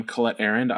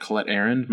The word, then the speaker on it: Colette Arrand: Again, I am Colette Arend. I am a staff writer at FanFight. You can find me on Twitter at Colette Arend. My name is spelled C-O-L-E-T-T-E-A-R-R-A-N-D.